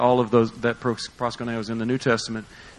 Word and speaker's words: proskuneo: The word says, this word is proskuneo. P-R-O-S-K-U-N-E-O. Proskuneo. All right, that's how you pronounce it.